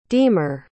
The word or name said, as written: deemer